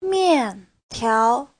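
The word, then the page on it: 面条: mian4tiao2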